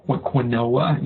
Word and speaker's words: quinoa: When they saw it spelled out, Q-U-I-N, OA, they had no idea how to pronounce it, you know, and quinoa, you know, but quinoa, that's how you pronounce it.